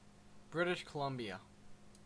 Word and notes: British Columbia: /ˈbɹɪt.ɪʃ ˌkə.lʌm.bi.ə/

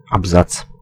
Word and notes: абзац: /ab.ˈzaʦ/

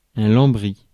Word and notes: panel: /ˈpæn.əl/

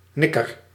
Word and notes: nikker: /ˈnɪkər/

